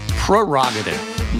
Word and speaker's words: prerogative: It's prerogative instead of prerogative, kind of like February instead of February.